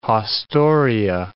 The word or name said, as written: haustoria